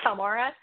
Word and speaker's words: Palmyra: Probably how the normal people – yeah, that's probably how normal people pronounce it. But if you're from here, you say Palmyra. Just like if you're from Louisville, you say Louisville, right? So yeah. – it's all in that dialect, right? – It's the old joke.